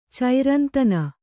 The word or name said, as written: ಚೈರಂತನ